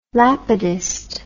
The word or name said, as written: lapidist